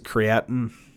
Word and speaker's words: creatine: Technically, it's a compound called creatine. Some people pronounce it creatine. Both are correct as far as I'm concerned.